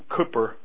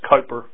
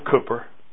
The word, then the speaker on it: Cowper: One of the greatest hymn writers, some of whose hymns are in our book, was a man called William Cowper, sometimes pronounced Cowper, but that's wrong. William Cowper.